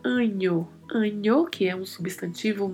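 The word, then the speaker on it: año: Então nós falamos anho, anho, como se fosse escrito com o NH.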